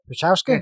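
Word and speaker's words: Procházka: Prokratchka? – Prochowska. – Very close. Very close.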